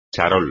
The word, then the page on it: charol: espagnol